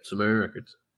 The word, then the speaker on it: Sumerian Records: Started off on 2015, and I hope I'm pronouncing the record label correctly, Sumerian Records. – Yep, Sumerian Records.